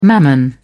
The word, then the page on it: mammon: British English